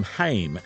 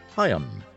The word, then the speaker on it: Haim: We've been calling them Haim, as in Corrie Haim, but that's wrong. It's actually pronounced Haim.